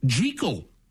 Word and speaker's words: Jekyll: Dr. Jekyll from Dr. Jekyll and Mr. Hyde was meant to be pronounced Jekyll that's how Robert Louis Stevenson intended it when he wrote the original story, and that's how they say it in the famous 1931 movie version.